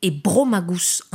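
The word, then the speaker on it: Eburomagus: Prononcé Ebro Magus en latin, avec accentuation sur le O, devenu Brom au Moyen-Âge, l'évolution de la langue et de la prononciation donnera Bram.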